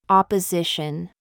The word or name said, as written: kit